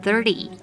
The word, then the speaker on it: thirty: 十三的 thirteen 重音在第二节的 teen，三十的 thirty 重音在第一节的 third，而且为了避免跟 teen 混淆。通常会稍微将字尾的 t 念成 a b c d 的 d，所以三十我们常常念的是 thirty thirty。